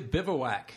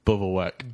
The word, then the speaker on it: Bivouac: Frontier Bivouac is our other land. How do you say this one? – Bivouac. – Bivouac.